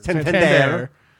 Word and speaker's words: Santander: Anthony Santander. I said that right. I'm not stuttering my words. Santander.